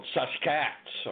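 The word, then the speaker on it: Sasq'ets: the word Sasquatch itself was a mispronunciation and a misspelling of a Chehalis word called Saskats, or basically take the word basket and drop the B and add S-A-S.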